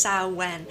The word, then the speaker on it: Samhain: Whereas there are other people who believe that it derives from Samhain, which is actually spelled Samhain, but it's pronounced Samhain. So maybe it's Samhain, it could be Samhain.